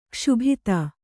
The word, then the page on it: ಕ್ಷೋಭಿತ: kṣōbhita